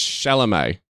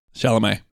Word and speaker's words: Chalamet: So it's Chalamet. – Chalamet.